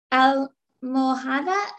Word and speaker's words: almohada: Almohada está. – La almo and the H is silent, like hola, almohada.